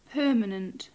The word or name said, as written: permanent